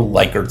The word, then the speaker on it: Likert: Use a 1 through 7 like a Likert scale. Some people call it a Likert scale, but it's actually pronounced Likert, believe it or not.